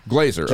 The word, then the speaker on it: Glazer: Glazer. – Just like Jay Glazer. – You got it.